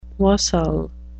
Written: wassail

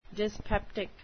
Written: dyspeptic

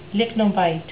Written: lychnobite

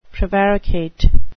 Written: prevaricate

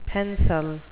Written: pencel

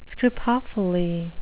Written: scripophily

